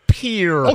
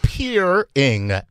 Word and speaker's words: Peering: She did. Peering. As in peer, that's my peer. Peer reviewed.